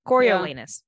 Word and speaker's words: Coriolanus: Coriolanus.